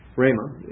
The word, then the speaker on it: Rhema: REMA is spelled R-H-E-M-A.